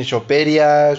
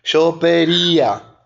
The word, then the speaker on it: Chopería: ¿cómo me dijiste que tiene que ser acento en la y porque luego dices que dice que dicen Choperia, que, que, quien sea que le cambie el nombre. Chopería. – Estás en Instagram así, ¿verdad? es chopería.